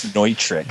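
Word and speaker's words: Neutrik: That's the way I really want to say it, but I think the proper pronunciation is Nutrick.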